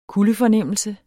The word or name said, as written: kuldefornemmelse